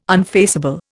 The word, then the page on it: unfaceable: Indian